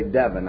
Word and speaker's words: Devin: And Devin, it rhymes with heaven. He was going to say Devin, I'm sure.